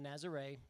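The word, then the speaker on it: Nazaré: So anyway, as he mentioned, we stayed in a village called Nazare. It's spelled Nazareth, similar to Jesus of Nazareth.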